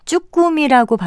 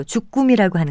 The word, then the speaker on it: 주꾸미: 일반적으로 단어의 첫 소리를 강하게 발음하려는 경향 때문인지 쭈꾸미라고 발음하고 쭈꾸미로 쓸 때가 많은데요. 이것은 표기도 주꾸미로 하고 발음도 주꾸미라고 하는 것이 맞습니다.